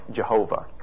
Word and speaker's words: Yahweh: Yahweh, by the way, is what we think might be the appropriate pronunciation of Jehovah, which is an interesting conflation of the consonants of one words and of the vowels of another, which in the Hebrew text are put together but are never meant to be read together.